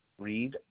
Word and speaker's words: Reed: Reed, R-E-E-D. – Okay, thank you. That was R-E-E-D.